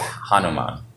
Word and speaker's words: Hanuman: So it's Hanuman. – Didn't know that. – No. – Hanuman.